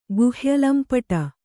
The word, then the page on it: ಗುಹ್ಯಲಂಪಟ: guhya lampaṭa